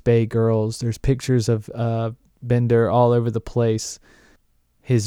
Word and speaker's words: Binder: And I just fell in with this Timothy Bender. I've heard it pronounced both ways, Bender and Binder. I noticed Lauren said Binder in the synopsis, but whatever is here or there, it's really not that important.